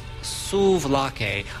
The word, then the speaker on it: Souvlaki: And Dave corrected me. It's Souvlaki. I'm attributing that mispronunciation to my parents who constantly mispronounced it as I was growing up.